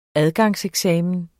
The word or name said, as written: adgangseksamen